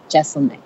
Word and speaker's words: Jeselnik: when I talk to him, I go, okay, so your name is Jeselnik. And he goes, no, it's S. It's Jeselnik. Like Wrestle-Nick.